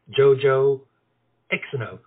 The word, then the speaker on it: Yoyo Xno: Yoyo Xno. – For you who don't understand the Swedish, it's spelled Yoyo, Y-O-Y-O, Xno, which is kisses and hugs, X-N-O.